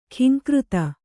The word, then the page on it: ಘೀಂಕೃತಿ: ghīŋkřti